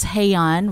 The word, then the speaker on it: Hey Yoon: Oh, so my name is spelled a little funny, and I always say it's Hey-Yon rhymes with Hey-Yon. – Gay fun.